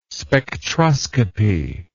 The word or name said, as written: spectroscopy